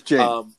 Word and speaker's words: Saoirse: I'm telling you, like, I've heard her pronounce her name, and she pronounces it Saoirse. Like, it's Jane. – No, it's Jane.